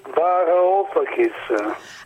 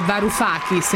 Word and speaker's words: Varoufakis: Yannis Varoufakis. – Ah, hai visto che... Perché tra l'altro noi Ferruccio in redazione lo chiamiamo Varoufakis, invece Varoufakis, okay. – E quindi in greco evidentemente l'accento va messo lì.